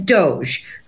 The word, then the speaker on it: DOJE: So my stage name today is Doge, but it is spelled D-O-J-E. – Okay. So it's not spelled like Elon Musk's whatever it is, D-O-G-E, right? – Right. It is not. It's pronounced the same. It is spelled differently because my D-O-J-E stands for the Department of Jhana Efficiency.